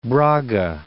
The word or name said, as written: Braga